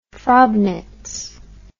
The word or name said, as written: frobnitz